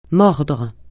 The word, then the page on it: mordre: francais